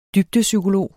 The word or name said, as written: dybdepsykolog